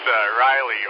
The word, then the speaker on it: Riley: And now, the other fellow that was in there, did you say your name was Rowdy, R-O-W-D-Y or something? – Trusty, it's Riley, R-I-L-E-Y, Romeo India Lima Echo Yankee.